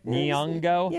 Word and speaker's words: Nyong'o: Nyong'o? – Yeah, Nyong'o.